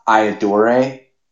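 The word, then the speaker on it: Ayyadurai: But I just love him in that because it, and I'm not saying I know how to pronounce Dr. Shiva's last name properly, but it's Iadore. – Yeah. – But Lucas adds this rolling R.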